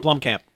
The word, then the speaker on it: Blomkamp: Neil Blomkamp? – Blomkamp, yeah.